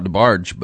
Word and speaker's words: DeBarge: Do you know DeBarge? – Oh, how I miss him. I used to call it DeBarge, but yeah, DeBarge. Yeah, it's French.